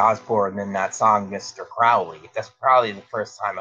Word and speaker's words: Crowley: I pronounce it I pronounce it Crowley, and I learned a rhyme a while ago that they said, "You be if you pronounce it Crowley, if you believed he behaved holy, and you Right. – pronounce it Crowley, if you believe that he behaved foully."